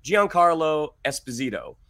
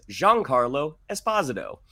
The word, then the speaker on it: Giancarlo Esposito: We have been saying Giancarlo Esposito. It is Giancarlo Esposito.